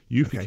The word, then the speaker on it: Eufy: Eufy is spelled EUFY.